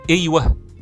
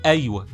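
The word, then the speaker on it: أيوه: اللي بتتنطق في المدن لما بنقول لما في الريف يقول ايوه تمام غير اللي في المدينه اللي بيقول ايوه بالفتح فايوه اللي في الريف هي اصح من ايوه لان اصلها اي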